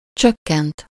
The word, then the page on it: ralentir: France; /ʁa.lɑ̃.tiʁ/; French